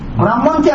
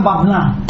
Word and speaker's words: ব্রাহ্মণ: একটা গল্ফ আছে, এক ব্রাহ্মণ, ব্রাহ্মণকে আমাদের বাংলাদেশে আঞ্চলিক ভাষায় কয় বাবনা, বাবন কয় বাবনাও, বাংলা ভাষায় সঠিক উচ্চারণ হল ব্রাহ্মণ।